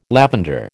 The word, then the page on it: lavender: American English